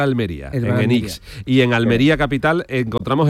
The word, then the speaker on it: Enix: ¿Qué es, Enix o Enix? – Enix. – Enix, vale. – Lo digo bien, ¿no? Sí, lo has dicho bien. – Terminado en X.